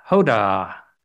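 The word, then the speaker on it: Hodah (הודאה): The commentaries explain that the name Yehuda, for Judah, shares the same root as the Hebrew word Hodiah, Hodah, H-O-D-A-A-H. Emphasis is on the H-O-D-A, which means acknowledgement or submission.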